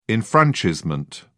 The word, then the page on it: enfranchisement: British English